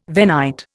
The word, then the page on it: Venite: Indian